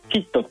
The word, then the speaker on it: HIIT: キット